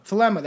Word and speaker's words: Thelema: How did I say it last time? Thelema. That's it. Kind of thelema. – Close enough.